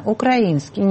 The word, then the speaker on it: украинский: А слово «украинский» они выучить никак не могут правильно ударение. Меня это поражает. Вот глава службы внешней разведки. Ну скажи правильно, украинский.